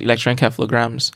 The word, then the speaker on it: electroencephalogram: Electroencephalogram or encephalogram. Electricity, encephalo, the brain, and gram is just like a message.